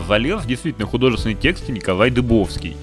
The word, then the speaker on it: Дыбовский: Я сейчас даже проверил на Ютубе, он Дыбовский себя произносит. – А я как сказал, Дубовский, да? Ну, может быть. – Не, нет, это я, я никак не, не знал, как правильно ударение ставить, он дыбовский.